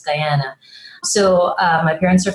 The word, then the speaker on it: Guyana: Tell me about you grew up in Guyana.